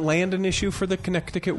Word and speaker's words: Connecticut: It's Connecticut.